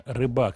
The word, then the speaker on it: Рыба: Михаил Рыба, ударение Рыба.